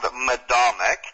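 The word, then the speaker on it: Medomak: Now, the camp is called Madomic, and am I pronouncing that correctly? – Yes, you are. – And I'm going to spell it for the listeners. It's M-E-D-O-M-A-K.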